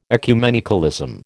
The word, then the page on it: ecumenicalism: American English